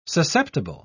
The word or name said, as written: susceptible